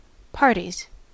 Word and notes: parties: American English

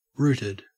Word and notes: rooted: Australian English

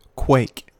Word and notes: quake: American English

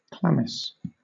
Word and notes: chlamys: British English

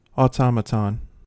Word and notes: automaton: American English

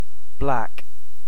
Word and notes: black: British English

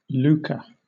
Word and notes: lucre: British English

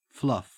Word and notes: fluff: Australian English

